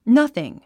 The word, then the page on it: nothing: nʌ’θiŋ; ナッスイン